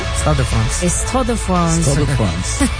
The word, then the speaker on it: Stade de France: ¿Cómo se pronuncia? – Stade de France.